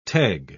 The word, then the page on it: teg: British English